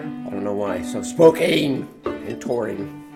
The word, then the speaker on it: Spokane: It's Spokane. – Oh, it is Spokane? – It rhymes with cocaine.